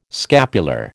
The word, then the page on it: scapular: American English